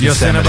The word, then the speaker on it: Yosemite: You didn't say it right, but we know what you meant. Yeah. Yes, Yosemite.